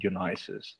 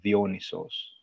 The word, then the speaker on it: Dionysos: So in Greek, we call him Dionysos. And English, I think, is Dionysus, and also Pan.